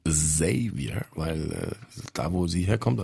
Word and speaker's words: Xavier: Und eigentlich ist es Xavier mit stimmhaftem S. Und da das immer so schwer zu erklären war, hat meine alte Plattenfirma 3P dann immer den Leuten gesagt, es wird ausgesprochen wie der englische Xavier.